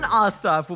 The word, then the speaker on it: Ossoff: Coming up next hour, Georgia Democratic Senator, well, Senate candidate, John Ossoff.